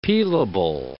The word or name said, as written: peelable